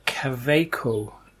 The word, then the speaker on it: Kaweco: So my first pen is called the Caveco, which is spelt K-A-W-E-C-O, Caveco Sport.